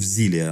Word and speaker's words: Xillia: И самое, что интересное, они так делали несколько раз, потому что у них была Tales of Xillia, э, а, Tales of Xillia, она, по-моему, правильно произносится. Вот.